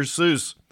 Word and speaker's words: Seuss: While in college, he began writing under the pen name Dr. Seuss. The way we pronounce it is an anglicized version of his Germanic middle name.